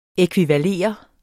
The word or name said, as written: ækvivalere